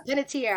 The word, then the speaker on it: Panettiere: Panettiere? – Yes. – Yeah, Panettiere.